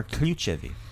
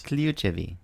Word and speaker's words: ključevi: The word for keys are ključevi. – Ključevi. – Yeah, I like that. Wait to say that correctly. Kluchevi. You said it right the first time. You can't go backwards. – Kluchevi.